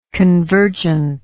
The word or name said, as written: convergently